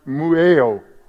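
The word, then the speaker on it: mueo (μυέω): And uh, I love it because instructed is a Greek word, uh, I think it's pronounced mueo, mueo, M U E O mueo.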